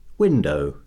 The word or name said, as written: window